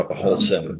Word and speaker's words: Holcim: I mean, that Aggregate and Holcim, if I'm saying that right. – No, Holcim. Yep.